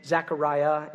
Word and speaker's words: Zechariah: Because when the angel tells Zacharias, or that's the Greek way of pronouncing his name, Zechariah is the Hebrew name way, so I'll probably toggle back and forth, you know.